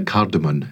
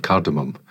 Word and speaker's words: cardamom: With food words, one thing that always grabs me when people get it wrong is when they say cardamom instead of cardamom, it's spelt with an M.